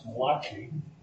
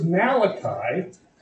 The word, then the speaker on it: Malachi: not as some have pronounced Malachi. He wasn't an Italian prophet, he was Jewish. But his name is Malachi,